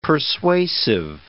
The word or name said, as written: persuasive